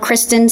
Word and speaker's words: Christin: My social handle, my first name is spelled Christin, C-H-R-I-S-T-I-N.